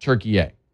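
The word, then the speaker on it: Türkiye: They're only now saying, by the way, we actually prefer it if you said Turkiye.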